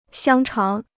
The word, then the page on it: 香肠: xiāng cháng